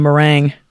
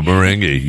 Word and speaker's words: merengue: Now I'm just thinking of meringue. – Meringue. No, merengue. You got it right.